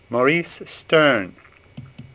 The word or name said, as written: Sterne